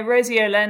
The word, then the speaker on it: roseola: What's roseola? Am I even pronouncing it correctly? – Yeah, no, roseola, another one of these lovely illnesses that most children will get at some point in their childhood.